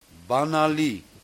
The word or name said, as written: Banali